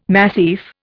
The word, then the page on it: massif: Indian